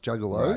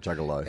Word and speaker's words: Juggalo: So apparently, according to Podcast Mike, the Juggalo, so it's pronounced Juggalo. – Right, Juggalo.